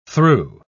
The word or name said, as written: through